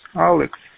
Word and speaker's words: Alex: My name is Alex. I spell it America, London, England, Dexray. Able, love, easy, X-ray. Alex.